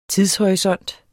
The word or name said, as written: TIDSHORISONT